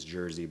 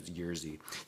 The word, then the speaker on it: Jerzy: And then, of course, it ended up being Jerzy Skolimowski, who for years I pronounced his name as Jersey, but I think the actual pronunciation is Jerzy.